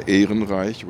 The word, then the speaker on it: Ehrenreich: Is it actually a similar crowd as I would pronounce her name Barbara Ehrenreich, which is the German.